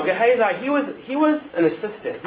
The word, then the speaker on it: Gehazi: How do you say his name? Someone help me with his name. – Gehazi, yes.